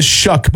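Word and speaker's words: Shough: Tyler Shuck, it is Shuck, by the way, pronunciation. It says in the article, it says the last name is pronounced Shuck, rhyming with words like buck or shucking corn.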